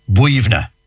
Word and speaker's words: Boyunagh: Well, you see, colloquially, like, for example, I know one, for example, one cemetery near Glenamedy, and it's spelled B-O-Y-U-N-A-G-H. B-O-Y. So you'd be saying Boyuna. No, yeah, not a bit of it. B-O-Y-U-N-A-G-H is pronounced Buivna.